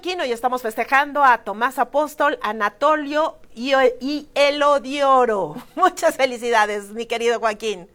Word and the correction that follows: Heliodoro: Heliodoro. – Heliodoro, sí lo dije mal, Heliodoro.